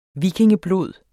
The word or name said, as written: vikingeblod